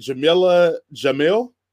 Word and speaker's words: Jameela Jamil: She Hulk has announced their uh, Titania and it's going to be Jameela Jamil.